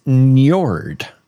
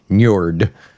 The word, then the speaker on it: Njord: It's Njord. – Njord. – Njord. – Can you spell that? N-J? – N-J-O-R-D. Njord.